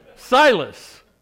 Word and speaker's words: Silas: Starts with an S, rhymes with Midas. Titus, it starts with an S. Silas.